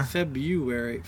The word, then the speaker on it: February: It has an R in there. – February.